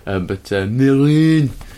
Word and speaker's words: Mesrine: which was Mesrine. – Mesrine. – Some French people pronounced it with the S. Gérard Depardieu did not. Mesrine.